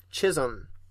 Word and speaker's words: Chisholm: It sounded like you said Chisholm whenever you pronounced it. And I was like, maybe I did. – I don't think it's pronounced that way. – Yeah, like the Chisholm Trail. – Yes, Chisholm, yeah.